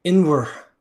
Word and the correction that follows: Ionawr: One more time. – Inwr. – It's Jonaur.